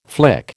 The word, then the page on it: flick: American English